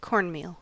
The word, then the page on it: cornmeal: American English